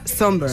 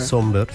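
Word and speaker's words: Sombr: Pues mira, yo busqué cómo se pronunciaba por si acaso, pero efectivamente se pronuncia Somber. – Somber, sí.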